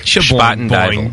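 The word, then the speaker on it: Spuyten Duyvil: Spoiten Duyvel. – Is that how you say it? – Yes. – Spoiten Duyvel. – Yes. – Not Spoiten Duyvel.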